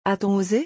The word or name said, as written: a t on ose